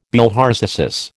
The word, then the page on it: bilharziasis: American English